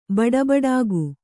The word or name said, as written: ಬಡಬಡಿಕೆ